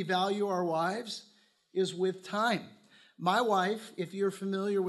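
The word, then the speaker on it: time (τιμή): It's the word time. That's how it's pronounced, time.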